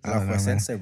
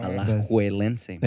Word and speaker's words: Alajuelense: Alajuelense, bro. – Alajuelense.